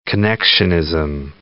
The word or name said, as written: connectionism